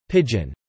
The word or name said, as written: pigeon